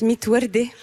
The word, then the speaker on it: Mit Werde: Electric Yasmine, votre album s'appelle Mid Verd et ça veut dire sans rose en arabe. – Oui, alors ça se prononce Mit Werde, pardon.